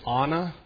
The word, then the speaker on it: Anna: Probably the Hebrew right way to say it is Anna. They pronounce their A's like father, like we do father.